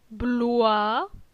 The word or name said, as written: Bloi